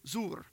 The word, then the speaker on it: zuwr: And it's this word, zur. Blam, you can't see it, can you? Okay, but anyway, it's spelled Z-U-W-R.